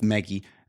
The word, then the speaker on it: Magi: It's with specifically the mega. Again, I'm saying it wrong again. With Maggie and the three gifts given.